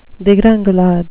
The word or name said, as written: degringolade